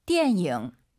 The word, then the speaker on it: 电影: 띠에닝, 니은 발음 들리면 안 돼요. – 电影.